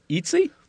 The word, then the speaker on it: Etsy: Etsy. Is that what it's pronounced? – Yeah. E-A-T. – Yeah. – No. – Yeah, Etsy. Eat. That's where people make weird food. – Etsy.